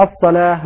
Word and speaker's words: الصلاة: بعض الناس يخطئ ويقرأ واستعينوا بالصبر والصلوات، والصلوات هذا خطأ، والصلاة الألف هذه مكتوبة واو فتنطق الصلاة ليس الصلوات.